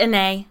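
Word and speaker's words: Anet: In the lands of Annette, or Annay. – Is it Annay? I guess it would be, because the T is silent in French. – Just, if you're doing your own research, it's A-N-E-T, like Annette.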